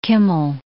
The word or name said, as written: Kimmel